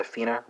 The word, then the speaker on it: finner: Norwegian speakers, you know it's pronounced fina, right? But it's the same spelling, F-I-N-N-E-R.